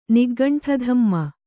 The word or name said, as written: ನಿಗ್ಗಂಠಧಮ್ಮ